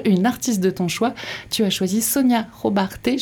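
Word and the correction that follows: Sona Jobarteh: je sais pas si je le prononce bien ouais c'est Sona Jobarté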